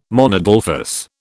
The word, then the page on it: monadelphous: British English